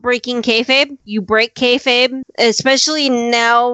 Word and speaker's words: kayfabe: And the original carnival term is K A Y F A B E. And I was going to pronounce that kayfabe. – Yes, you are right.